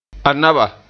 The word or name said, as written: Anaba